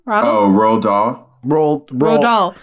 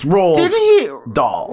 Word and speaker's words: Roald Dahl: Didn't he also, Rodell? How do you say his name? – Roald Dahl. – It's Roald Dahl.